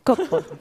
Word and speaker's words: Coppola: Francis Ford Coppola. – That is correct. Jonathan Colton, how was the Italian in that answer? – Coppola. – Coppola, thank you very much.